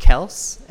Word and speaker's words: Kelce: Travis Kelce / Kelce, as he said it's actually pronounced, at tight end.